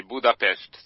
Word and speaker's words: Budapest: Eh, ¿es Budapest? ¿Se pronuncia así? – Eh, se pronuncia exactamente así, Budapest, sí.